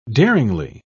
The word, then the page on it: daringly: British English